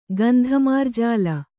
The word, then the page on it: ಗಂಧಮಾರ್ಜಾಲ: gandha mārjāla